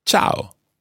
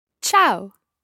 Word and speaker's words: ciao: Ciao. – Ciao. Now, be careful with the pronunciation of this word. Quite often people think it's ciao, almost as if it's spelled C-H-O-W, but it's not. It's ciao.